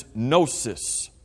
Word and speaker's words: gnosis: T-I-C-I-S-M or whatever the rest of that is. But it starts with the letter G. So you've got a silent G on the beginning of it. I mention that because the Greek word for knowledge, in the Greek language, the word for knowledge is gnosis. G-N-O-S-I-S.